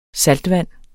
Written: saltvand

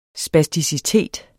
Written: spasticitet

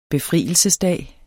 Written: befrielsesdag

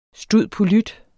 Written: stud.polyt.

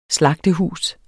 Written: slagtehus

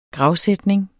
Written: gravsætning

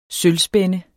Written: sølvspænde

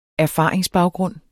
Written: erfaringsbaggrund

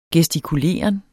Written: gestikuleren

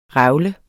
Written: revle

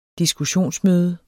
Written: diskussionsmøde

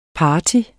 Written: partyer

